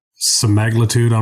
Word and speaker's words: semaglutide: This is Medical Spa Insider, and uh, we are continuing our conversation today about semiglutide, uh, semi semiglutide, right? So that's how you pronounce it. And I've heard of many different ways. I've actually heard of another way to pronounce this semaglutide. I'm not sure if you've heard of that one, um, but um, also known by its brand name Ozempic and what I believe, is what it's called.